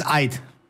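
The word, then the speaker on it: Aït: Ryan Aitnouri, acá necesitaría la ayuda de Diego Iglesias para pronunciar correctamente, sí. Pero bueno, no pudo ser. 46,45 millones. Amin Gouri. – Cuando tenés la diéresis es Ait.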